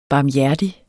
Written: barmhjertig

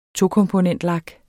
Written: tokomponentlak

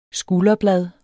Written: skulderblad

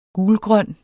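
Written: gulgrøn

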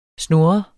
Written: snurren

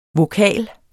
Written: vokal

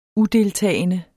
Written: udeltagende

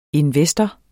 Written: INVESTORERNE

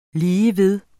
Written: ligeved

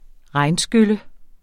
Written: regnskylle

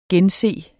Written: gense

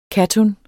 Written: kattun

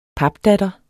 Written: papdatter

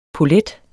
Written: polet

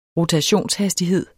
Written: rotationshastighed

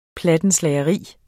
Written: PLATTENSLAGERI

